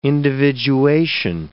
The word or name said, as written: individuation